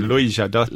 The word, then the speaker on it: Louis Jadot: Louis Jadot.